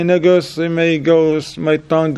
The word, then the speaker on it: Negus: Negus. I'm saying that right? – Negus. – Negus. I don't want it to mix it with the other one. All right, Negus. All right, mashallah. All right, Negus. N-E-G-U-S.